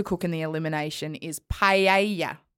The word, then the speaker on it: paella: Sondra, who loves paella, pronounces it paella. – Paella. – Yeah, so Sondra, who knows how to cook paella. – Because she's Spanish.